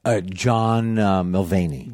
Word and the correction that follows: Mulaney: John Mulaney. – Mulaney.